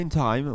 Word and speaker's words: Interheim: Alors Interheim, ça se prononce comme ça? – Oui, Interheim, oui. – Ouais, Interheim, ouais. C'est alsacien, c'est... – On dirait germain, de... ouais, c'est plutôt allemand.